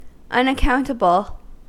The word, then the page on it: unaccountable: American English